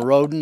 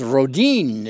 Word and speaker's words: Roden: The name Roden is pronounced just as I've said it, Roden in English, but is said as Rodin in Swedish.